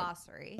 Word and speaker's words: Basseri: Basseri.